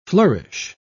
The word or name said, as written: flourish